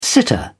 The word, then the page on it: sitter: British English